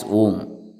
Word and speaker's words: Om: And this is the Om and the Om only, because these three letters are U, Ma, Om, pronounced A-U-M, pronounced in combination as Om.